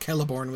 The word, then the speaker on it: Celeborn: No one's going to look at K-E-L-E-B-O-R-N and mispronounce that, right? Celeborn, clearly it's pronounced Celeborn with a K, right? When he changes it from Celeborn with a K to Celeborn with a C, the pronunciation doesn't change,